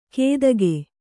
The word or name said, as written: ಕೇದಗೆ